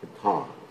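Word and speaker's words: Pitri: Pitta, it's actually how you say it.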